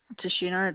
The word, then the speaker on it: Tashina: Tashina, it's spelled TASHINA.